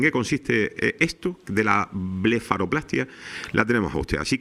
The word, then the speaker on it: blefaroplastia: Blefaroplastia. ¿Lo he dicho bien, doña Cristina? – Perfecto.